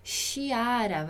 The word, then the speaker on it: Chiara: Chiara. Mas sempre vamos escrever assim. Então, é importante lembrar que o CI não vai ser chi, não vai ser chiara, vai ser sempre ki, chiara, ok?